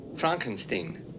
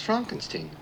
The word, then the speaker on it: Frankenstein: Frankenstein. You're putting me on. – This is when Igor. – It's pronounced Frankenstein. Do you also say Frederick? – No. Frederick. – Well, why isn't it Frederick Frankenstein? – It isn't.